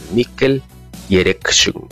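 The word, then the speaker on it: Mikel Erentxun: Porque es vasco, y ese es un nombre y un apellido vasco, así se pronuncia, Mikkel Erentxun.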